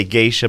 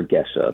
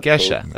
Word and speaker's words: Gesha: The original name comes from a region of Ethiopia, so it's spelled G-E-S-H-A, so it's pronounced Gesha, but it's added someplace along the way, it got this I added, and it's also said as Geisha.